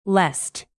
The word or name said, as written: lest